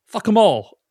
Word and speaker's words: Fackham Hall: As they pronounce it in the movie, fuck them all.